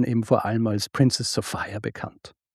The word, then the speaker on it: Sophia: Geschrieben Sophia, aber entsprechend der damaligen Mode auch wird sie tatsächlich Sophia ausgesprochen. Dass sie sie bei ihrem Vornamen nennt, das hat mehrere Gründe.